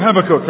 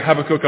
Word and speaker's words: Habakkuk: you say Habakkuk and we say Habakkuk. But fortunately, apart from learning that we pronounce the word differently, so if you're Americans here, please forgive me, when I say Habakkuk, I mean Habakkuk.